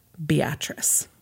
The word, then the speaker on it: Beatrice: The day had been bitterly cold in Beatrice, Nebraska. Spelled like Beatrice, pronounced Beatrice.